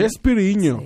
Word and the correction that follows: Chespirito: ¿Cómo se dice? – Chespirito. – Chespirito.